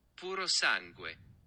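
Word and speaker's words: Purosangue: Puro Sangue.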